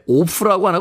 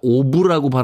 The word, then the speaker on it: of: 근데 오프라고 안 하고 오브라고 발음하지 않습니까? 예.